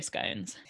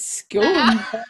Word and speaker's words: scone: I was on board until you pronounced it scone. So jarring. – I knew you were gonna say that. – I can't say scone, because to me scone is posh.